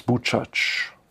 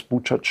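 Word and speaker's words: Buczacz: dessen Mutter aus Bucac, so spricht man es aus, aus Bucac stammte.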